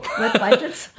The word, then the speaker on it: Web Vitals: äh, zu haben, der neue Ranking-Faktor, die kommen, äh, Web Vitalis. Habe hab ich es richtig ausgesprochen? Web Vitalis? Web Vitalis. – Vitals. Oh. Web Vitals.